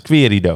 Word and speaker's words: Querido: Zeg ik het goed? Querido. Querido.